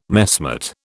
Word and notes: messmate: British English

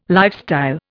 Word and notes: lifestyle: Indian

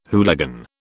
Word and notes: hooligan: British English

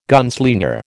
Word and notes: gunslinger: American English

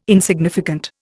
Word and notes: insignificant: Indian

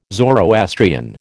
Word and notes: Zoroastrian: American English

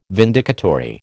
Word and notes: vindicatory: American English

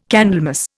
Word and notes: Candlemas: Indian

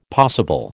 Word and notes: possible: American English

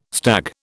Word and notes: stag: British English

